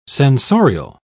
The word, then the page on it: censorial: British English